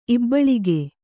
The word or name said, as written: ಇಬ್ಬಳಿಗೆ